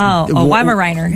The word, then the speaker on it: Weimaraner: It's Weimaraner.